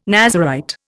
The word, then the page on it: Nazarite: Indian